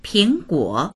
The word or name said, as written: ping2guo3